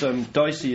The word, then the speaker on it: DSEI: That's DICEY is spelled D-S-E-I, just so people know.